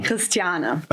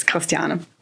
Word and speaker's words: Christiane: So I don't even try to say my name in any English version. There is just... It's Christiana.